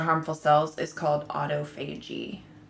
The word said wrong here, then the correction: autophagy: A-U-T-O-P-H-A-G-Y. – That's not how you pronounce it. – How do you pronounce it? – Autophagy. – Autophagy. – Mm-hmm. – Okay. – But it's spelled auto.